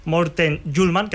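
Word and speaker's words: Hjulmand: De momento, el Atlético de Madrid parece cerca de cerrar un nuevo fichaje, el del centrocampista danés Morten Juhlmann, que así se pronuncia, que ha sido visto a lo largo del día por la capital.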